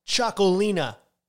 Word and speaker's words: Txakoli: And here is where the word Chacolina comes into play. Chacoli, which in the Basque language is spelled T-X-A-K-O-L-I, roughly means village.